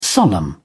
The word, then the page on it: solemn: British English